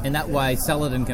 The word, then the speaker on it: Saladin: It's Saladin. Not Saladin.